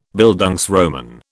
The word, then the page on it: Bildungsroman: British English